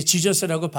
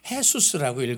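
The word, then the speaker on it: Jesús: 근데 지저스라고 발음을 안 하고요. 헤수스라고 읽어요. J는 H 사운드가 나니까.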